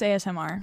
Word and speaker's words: ASMR: It's asthma, right? – Totally. – ASMR. I don't know. I probably pronounce it asthma. Is it not? – I think it's ASMR.